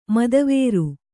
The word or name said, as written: ಮದವಾರಿ